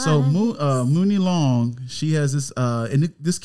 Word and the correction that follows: Money Long: It's pronounced Money Long. – Money Long, sorry. What did I say? I said Mooney Long, my bad.